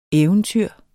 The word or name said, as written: eventyr